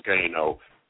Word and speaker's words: SKNO: It's spelled SKNO, and there's a long line of other K, which makes you pronounce it phonetically SKNO.